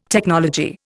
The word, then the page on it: technology: Indian